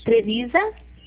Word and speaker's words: Trevisa: É TREVISA. – SA. Isso. – Trevisa.